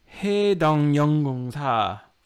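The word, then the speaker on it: Haedong Yonggungsa: It was a place called, ready for this pronunciation, Haedongyeonggungsa. – That was very good.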